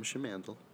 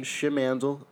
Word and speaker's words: Schimandl: My name is Tom Schimandl. – Slower. I have it. Tom Schimandballs, Tom Schimmy, Tom Schimmy. – Schimandl. I was always told by my mom that it rhymes with candle.